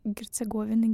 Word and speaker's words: Герцеговины: И после Черногории и Боснии и Герцко... Герцеговины, Герцеговины.